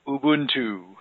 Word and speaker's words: Ubuntu: Well, if you go by the official pronunciation by the folks at Canonical, it's Ubuntu.